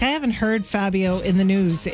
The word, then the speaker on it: Fabio: Kind of rhymes with radio, sort of, but not really. – Fabio! – Yes. – Fabio.